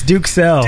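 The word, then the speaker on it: Duxelles: That's how it's pronounced. It's Duxelle.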